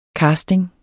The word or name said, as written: casting